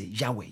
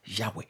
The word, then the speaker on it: Yahweh: Okay, it's not J, it's Y. That's why some people say Yahweh. Just put A and Yahweh. Okay, but it's YHWH in the Hebrew, which they don't pronounce it, but it sounds like Yahweh.